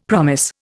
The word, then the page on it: promise: Indian